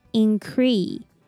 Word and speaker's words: increase: The first IN sounds like ing, ing. And the C pronounces k. And ER is E, increase, increase. Unless we add S, increase. And remember to stress the second syllable.